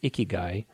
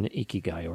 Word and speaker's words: ikigai: You know, the Japanese have this thing called ikigai.